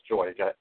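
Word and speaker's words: Joique: It's spelled French, but it's pronounced Joy.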